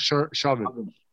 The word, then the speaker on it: Chauvin: More importantly, we finally got that verdict for George Floyd's killer, Derek Sherman. That's how you say his name? Chauvin? Derek Chauvin. – Yeah.